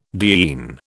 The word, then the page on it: diene: British English